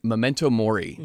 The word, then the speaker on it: Memento Mori: Memento Mori, yeah, which is Latin for you will all die, or we all, something like, you know, you have to die.